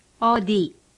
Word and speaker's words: 어디: When you see a D, you should pronounce it like the D in day. For example, 어디.